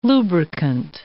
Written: lubricant

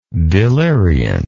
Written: deliriant

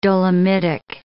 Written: dolomitic

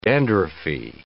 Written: dandruffy

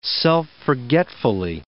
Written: self-forgetfully

